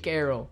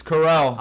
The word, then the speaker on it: Carell: How do you pronounce Steve Carroll? – Steve Carell. – Back on this. It's Carell.